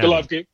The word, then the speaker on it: Golovkin: Yeah, Golovkin.